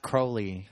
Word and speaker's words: Crowley: It was it's pronounced Crowley. – It's Crowley. – In the book, it tells you how to pronounce it.